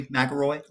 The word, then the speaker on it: McElroy: I have in the TVS podcast studio guest athletic trainer Symone McElroy, and I have been pronouncing your last name correctly, McElroy? – Yes, that is how you pronounce it.